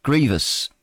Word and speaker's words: grievous: Primary stress is on the first syllable, is it? Grievous. Not grievous. Causing grief, pain, or anguish.